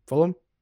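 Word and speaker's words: Fulgham: Uh, can, how do you pronounce his last name? F- Fulham. – Fulham. – G is yeah, silent.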